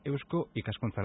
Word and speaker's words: Eusko Ikaskuntza: Y hablamos también con Pedro Gorostategui, presidente de la sección de Ciencias de la Salud de Eusko Ikaskuntza. Lo he dicho bien, ¿verdad? – Sí, muy bien.